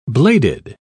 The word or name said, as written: bladed